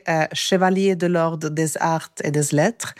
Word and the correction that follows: Chevalier de l'Ordre: Förlåt, jag måste säga det igen för det var sjukt dåligt uttal faktiskt. – Chevalier de l'Ordre des arts et des lettres.